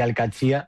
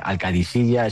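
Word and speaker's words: Al-Qadsiah: Bueno, se suele decir Al-Qadsi, pero también hay gente que lo dice Al-Qadisiya, es que es difícil, ¿eh? No es fácil, pero Al-Qadsi es lo más fácil.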